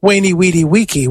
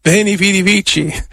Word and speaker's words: veni, vidi, vici: Well, if you pronounce it with what we think was the classical pronunciation, it's waini, weedy, weeky, which sounds a little bit like a summer camp. Camp waini, weedy, weeky. No, veni, vidi, vici.